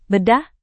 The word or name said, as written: bedah